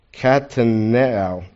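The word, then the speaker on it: katanoeo: Katanao. Did I say it right? Yeah, okay.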